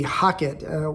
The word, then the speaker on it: Heqet: It's spelled H-E-Q-E-T, Haket, was